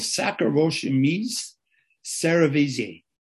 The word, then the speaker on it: Saccharomyces cerevisiae: Yeast is a single cell organism called Saccharomyces cerevisiae. It's pronounced exactly like that, which needs warmth, food, moisture to survive.